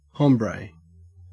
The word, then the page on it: hombre: Australian English